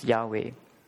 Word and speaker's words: Yahweh: So you have YHWH, which pronounces Yahweh.